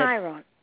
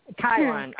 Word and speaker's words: Chiron: How do you pronounce it? – Chiron. – Chiron, okay.